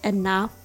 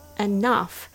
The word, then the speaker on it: enough: Do you think you had enough of my pronunciation lessons? Here's another one. Enough is pronounced with uh, the schwa sound. Uh, enough enough. Enough. Okay, I can't get enough of talking about the proper pronunciation.